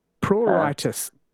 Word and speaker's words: pruritus: Pruritus. Pruritus. So this is Pruritis.